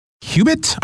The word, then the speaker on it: qubit: We hear about bits and bytes in computers, but in quantum computing, there's something called a qubit, I think I'm pronouncing it right. What exactly is that? – That's correct.